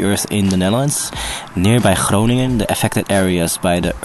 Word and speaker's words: Groningen: Thank you for pronouncing Groningen properly.